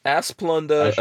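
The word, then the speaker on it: Asplundh: Asplunda, a Swedish word for a grove of aspen trees, is pronounced Asplund. The first syllable rhymes with ma. Second syllable, fund.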